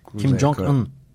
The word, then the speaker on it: Kim Jong-un: Benim kadar iyi telaffuz edebildiğimi bilmiyorum. – Kim Jong-un.